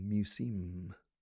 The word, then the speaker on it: museum: Museum. Museum. That's the correct pronunciation. Spanish people mispronounce museum a lot.